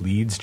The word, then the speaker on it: Leeds: And I'm on Twitter at my name, which is Jeff Leeds, J-E-F-F-L-E-E-D-S.